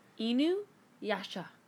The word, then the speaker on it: Inuyasha: Inuyasha. – Why did I ask? – Yeah, that's actually how you say it. Thank you very much. – One more time, what was it? – Inuyasha.